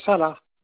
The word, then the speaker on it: Salah: Salah. – Alors, ça c'est votre nom, ça s'écrit comment, monsieur? – S comme Sophie, A-L-A-H.